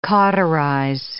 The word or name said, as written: cauterize